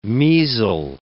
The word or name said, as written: measle